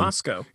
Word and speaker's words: Moscow: It's spelled just like Moscow, Russia, but it's pronounced Moscow. – All right, Mikhail. – Oh, Moscow.